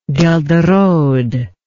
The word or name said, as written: ghelderode